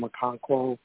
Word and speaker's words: Okonkwo: The one guy still touting week after week, Chigozie Okonkwo,